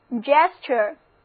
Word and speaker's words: gesture: 我们再来看这个单词 gesture，在表示做的词根 gest 之后加上 ure 的名词后缀 gesture，组合 ture 读作 ch，跟我读这个单词就是 gesture。